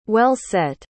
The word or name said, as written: well-set